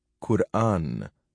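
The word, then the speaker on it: Qur'an: This is not the case with the eminently correct transcription, Quran, Q-U-R, apostrophe A-Makron-N, which is now in wide use in English, though not, for example, in German or French. The stress is on the second syllable.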